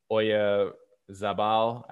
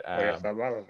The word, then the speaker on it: Oyarzabal: And then I'm going to butcher his name, Mikel Oyarzabal. I butchered it. Oyarzabal. But that's impersonation.